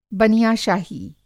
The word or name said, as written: बनियाशाही